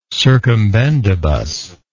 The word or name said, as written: circumbendibus